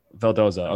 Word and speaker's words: Vildoza: How do you pronounce his last name? Forgive me. – Vildoza. – Vildoza, okay.